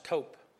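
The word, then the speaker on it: tope (תֹּף): It's spelled T-O-P. It's really pronounced tope.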